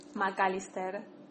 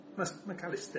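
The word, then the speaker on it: Mac Allister: So how do you say his name? – McAllister. – McAllister. It, yeah, that's right.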